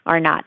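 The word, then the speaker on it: R naught: It's like R sub zero. R-naught is how it's pronounced.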